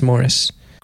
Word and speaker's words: Maurice: Spelled Maurice, pronounced Morris, producing a cross current that causes a boat to buck and shoot through that section.